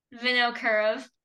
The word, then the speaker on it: Vinokurov: Vinokurov.